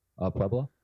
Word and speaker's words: Puebla: There's only the city of Puebla. I hope I'm saying that right. – I don't know. Who cares? – I think you are saying it right. It's like Pueblo with an A at the end.